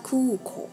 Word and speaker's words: 空港: 空港念作 kuko，kuko。那成田空港念作 narita kuko。